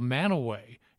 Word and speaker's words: Mantua: Here in Salt Lake City, I'm in Logan, Utah, but in Salt Lake City, they'll come in and they'll mispronounce a town, a little area called Manaway. It's spelled Mantua.